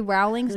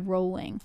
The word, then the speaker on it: Rowling: FYI, J.K. Rowling's name is pronounced like bowling. Yeah. Row your boat or rolling down a hill. So it's J.K. Rowling.